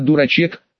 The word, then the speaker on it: дурачек: Не дурачок, в именно дурачек, с ударением на «у».